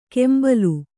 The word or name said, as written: ಕೆಂಬಲು